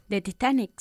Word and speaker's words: Titanic: The Titanic sank in 1912. – Good, but we pronounce it the Titanic.